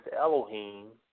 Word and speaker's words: Elohim: You spell that E-L-O-H-I-M. That's the English word. That's it translated in English, E-L-O-H-I-M, and pronounced in English. I believe that's how you pronounce in the Hebrew, too, Elohim.